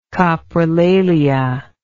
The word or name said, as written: coprolalia